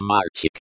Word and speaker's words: мальчик: The stress in мальчик is on the first syllable.